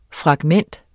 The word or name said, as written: FRAGMENTET